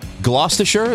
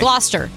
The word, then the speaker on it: Gloucester: Well, the person who invented that smell or decided on it is a man from Gloucestershire. Is that how you pronounce it? – I think it's a little bit more smushed. – Gloucester. – Okay. Gloucester.